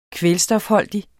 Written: kvælstofholdig

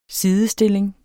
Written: sidestilling

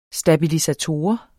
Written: stabilisator

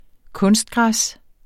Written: kunstgræs

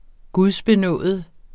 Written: gudbenådet

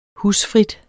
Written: husfrit

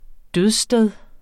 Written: dødssted

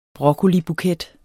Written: broccolibuket

